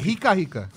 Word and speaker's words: rica rica: Eu acho que é assim que se pronuncia, na verdade. Se eu tiver errado, por favor, me corrijam. Vocês podem mandar o jeito correto de se pronunciar o nome dessa erva pro nosso WhatsApp. – É Rica Rica.